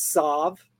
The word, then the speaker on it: Tsav: I'm not exactly sure how it's pronounced, but I think it's Tsav. The T is silent.